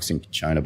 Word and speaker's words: cinchona: But the truth is it's basically sugar syrup with pinkona bark, which has various different pronunciations depending on which branch of Latin language you might subscribe to. – All of them. I subscribe to all of them. – Yeah, the pronunciation is a bit of a thing. – So it can be cinchona bark, cinchona bark, cinchona bark. The CH and the C, depending on which country you're in and which vowel it follows, all sort of change.